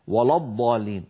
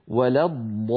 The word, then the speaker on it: ولا الضالين: أيضا هنا لما نقول غير المغضوب عليهم ولا الضال، فاللام في ولا جاورت الضاد المفخمة، فلعلها تتأثر بها فينطقها القارئ خطأ ولا الضالين ولا الضالين، هذا لا يصح أبدا، بل عليه أن يقول ولا الضالين.